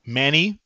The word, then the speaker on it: Manny: Yeah, feel free to leave any comments or follows at Manny, M-A-N-N-Y, Benavides, B-E-N-E-V-I-D-E-S.